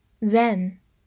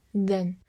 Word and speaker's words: than: then, t h a n, then, t h 发 z，a n 发 n, then。当然，在弱读的情况下，也可能读作 then。